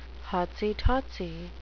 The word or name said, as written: hotsy-totsy